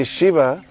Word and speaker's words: Shiva: It's actually Shiva, our Lord Shiva, is a very short eye.